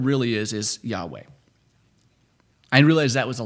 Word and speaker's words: Yahweh: Long story short, Jehovah's not right. The way you pronounce God's name is Yahweh.